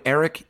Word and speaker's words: Erik: Anyway, you're going to get your first shipment free when you use the special promo code Erik, E-R-I-K.